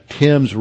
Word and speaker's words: Thames: is spelled T-H-A-M-E-S, but they've just completely corrupted the pronunciation. They call it the Thames River.